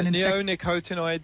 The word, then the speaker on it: neonicotinoid: Yeah, neonicotinoid pesticides.